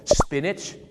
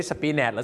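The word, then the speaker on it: spinach: สปินาชไม่ใช่สปีแนทหรือสปีนาทนะฮะสปินาชนั่นเองครับออกเสียงว่าสปินาช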